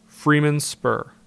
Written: Freeman Spur

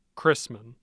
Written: chrisman

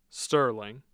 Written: Sterling

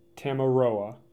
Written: Tamaroa

